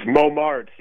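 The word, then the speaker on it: Mommertz: But Cody, am I saying it right? It looks like a Dutch name. You say Momertz or Momertz? – It's Momertz. Yeah, it's Dutch and Hungarian. But you're close enough.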